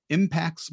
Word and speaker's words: Impaxs: My guest today is Alex Sheridan, the founder and CEO of Impacts Marketing, and that's spelled I-M-P-A-X-S.